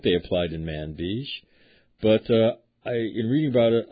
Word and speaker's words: Manbij: I say manbij. How do you say that? – Me too, manbij.